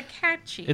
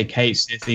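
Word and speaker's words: Cait Sith: It's a Ketshi. – It's a Ketshi, but I don't know how people actually say it. – Most people just say Ketsith, even though I think that's incorrect.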